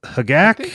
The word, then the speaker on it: Hogaak: Did you just call it Hogak? – Hogak. He has been Hogak calling it Hogak. Hogak? – Which one is it? Uh, it's it's it's Hagak.